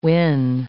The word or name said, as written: whin